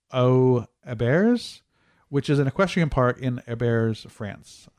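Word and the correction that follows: Les Herbiers: I'm probably saying that wrong. It's Herbiers.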